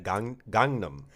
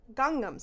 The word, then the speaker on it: Gangnam: Did you say Gundam? – Nope. – Like Gundam Wing? – I tried to make it so that I said it fast because I don't know how to say it. – Gangnam. – Gangnam Style. That was pretty good.